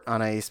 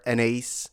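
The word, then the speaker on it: Anaïs: So if you have a chance to do that, please do, support Anais Mitchell or Anais, for those who pronounce it differently than I do.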